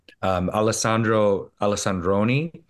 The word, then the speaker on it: Alessandroni: Alessandroni.